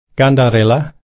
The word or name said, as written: kandarilla